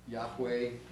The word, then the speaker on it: Yahweh: Yahweh is how we sometimes pronounce it.